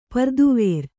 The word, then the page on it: ಪುರದ್ವಾರ: pura dvāra